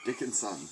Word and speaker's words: Dickinson: and it is spelled exactly how it sounds, Dick and Son. – Yes, I've been Dickinson. That shit has me fucking dead. – Dickinson. – Dickinson. It's spelled Dick and Son. – Even though it's pronounced Dickinson.